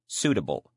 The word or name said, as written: suitable